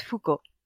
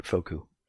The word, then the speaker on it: Foucault: It doesn't look like it's easy to say when you read it because there's. Uh, it's got all the extra letters. random crap that the French don't pronounce. Yeah. – Yeah, and uh, there's a lot of vowels too, but it's just Foucault. – And yet, Carl Gahn managed to get it backwards, made it Foku, and then Kevin and Christy laughed at him.